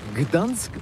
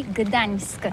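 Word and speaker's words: Gdańsk: Ça s'écrit G-D-A-N-S-K. On dit Gdansk ou Dansk, on prononce le G ou pas?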